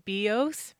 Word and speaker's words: bios: But one of them is bios, B-I-O-S. Like biology.